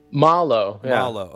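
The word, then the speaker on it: Malo: Is it Mallow? – Mallow. – Okay. – Mallow, which essentially means bad or ill in Spanish.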